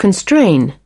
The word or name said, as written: constraining